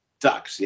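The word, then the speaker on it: Duhks: It's spelled D-U-H-K, and it's a family band like the Rankin family. They call themselves Ducks, yeah.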